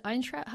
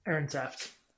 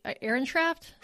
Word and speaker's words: Ehrensaft: The particular clinician quoted in this piece, Diane, is it Eintraft? How do you pronounce her name? – Ehrensaft. – So Diane Ehrensaft, Ehrensaft? – Ehrensaft. – Ehrensaft. Okay. Diane Ehrensaft.